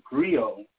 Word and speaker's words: griot: So what is commonly known as a griot, the T is silent.